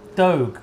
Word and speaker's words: Dogue: Dogue rhymes with Vogue.